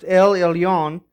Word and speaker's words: El Elyon: The term and title Most High, it is a Hebrew title pronounced El Elyon, and it's a title given to God alone.